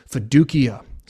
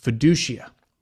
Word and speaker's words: fiducia: The second Latin term used by the reformers is fiducia, sometimes pronounced fiducia, and it simply means trust.